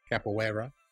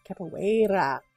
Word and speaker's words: Capoeira: Glad I googled how to say this. Capoeira. – Capoeira.